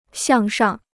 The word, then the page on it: 向上: xiàng shàng